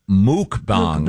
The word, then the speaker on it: mukbang: It's actually pronounced muk, yeah. – Is it muk? – Mukbang. – Yep. Oh, I thought it was mukbang. – The pronouncer here is M-O-O-K. – Oh, okay. I've always heard muk. The people who do it, I've heard say muck. – It's spelled M-U-K-B-A-N-G.